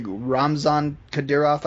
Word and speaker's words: Ramzan Kadyrov: It's like Ramzan Kadyrov, I believe. – That's just right.